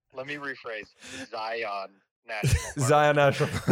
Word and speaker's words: Zion: Well, said it incorrectly, but that's, you know, no, that's great. – Zion's. – I think there's just one. – Zion's? Yeah, it's just Zion. It's the pluralization. Just Zion National Park.